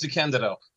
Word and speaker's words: DeCandido: By the way, it's pronounced DeCandido.